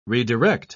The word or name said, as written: redirect